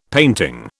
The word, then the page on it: painting: British English